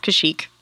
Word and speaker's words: Kashyyyk: It's Kashyyyk.